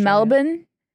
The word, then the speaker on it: Melbourne: I've actually never been to And it's pronounced Australia. Melbourne? – Melbourne. – Not Melbourne. – Oh, yeah. Some people say it like that. I think like that's like a strong accent.